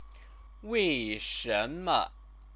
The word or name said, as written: weishenme